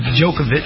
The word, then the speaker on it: Djokovic: And we're going to also cover a story that happened recently that I find ridiculous involving Djokovic, the tennis star. Is it Djokovic? Even though it's just a C at the end, they pronounce it with the C-H.